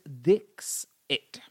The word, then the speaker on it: Dixit: So it's Dixit. – Dixit, so Madhuri Dixit. – D-I-X-I-T is her surname, but her first name is Madhuri.